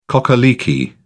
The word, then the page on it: cock a leekie: British English